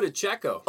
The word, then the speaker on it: DiCecco: Nico DiCecco. – Oh, DiCecco. I was like, DiCecco. That would not have been right.